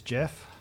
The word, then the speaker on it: GIF: The correct pronunciation is Jeff.